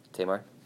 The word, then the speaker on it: Tamar: And then, I don't know if Tamar or Tamar, does anybody know how exactly to pronounce it? It's Tamar.